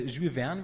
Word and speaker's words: Jules Verne: Ursprünglich Captain Nemo, natürlich ein Jules Verne, wird es glaube ich aussprechen, ein Charakter. – Ja, ist richtig. Korrekt.